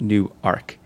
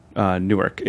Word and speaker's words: Newark: New Ark. And some people took me pretty seriously, but the thing about Newark is, or Newark, is that it's not pronounced New Ark or Newark.